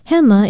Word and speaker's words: hema: Aloha, I'm Lilani Poliahu of Hawaii Public Radio, and our Hawaiian word for today is hema. Hema is spelled H-E-M-A, and it means left or left side.